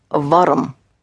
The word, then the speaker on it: warm: The word for warm is warm. So it's spelled exactly the same. W-A-R-M. Warm. Warm.